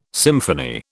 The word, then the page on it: symphony: British English